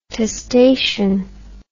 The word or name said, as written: testation